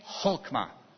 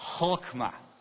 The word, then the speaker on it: Chokmah: Chokmah. Now, you're not saying it with enough spit. If you don't need to reach for your coffee cup afterwards, then you're not saying it right.